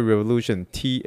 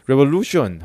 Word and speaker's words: Revolución: Panda Express 之前，我们就是还是在用上网再查一下，就发现说，诶，有这个司机说昨天所提到的这间这个 taco 店叫做 Taqueria Revolution，Taqueria Revolution，Taqueria Revolution，T A Q U E R I A Revolution R E V O L U C I O N 哦，Revolution 就觉得这 Revolution，Revolution 哦。 Lucian，Lucian，Revolution，对不对？我太太在纠正我这个西班牙语的这个发音，因为她会有学一点点这样。 Revolution，哈，就 Taqueria Revolution，哦，就是这一这一句话，这句话。